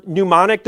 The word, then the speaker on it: mnemonic: I had a teacher there who has since gone on to be with the Lord, who taught us, what's that term, mnemonic device? Am I saying that correctly? A way to remember things? Okay, Kate's confirming that.